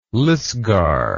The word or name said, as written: lisgar